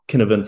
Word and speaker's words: Cynefin: It's pronounced Cynefin from the Welsh, but it's C Y N E F I N, I think, Cynefin framework.